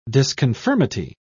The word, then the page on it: disconformity: British English